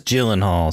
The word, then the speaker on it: Gyllenhaal: But you said Gyllenhaal, and it is Gyllenhaal, so there's some confusion there, you know, because that's a G. I think it could be like a hard G, should be a hard G, Gyllenhaal, if you'd never seen it or heard it before.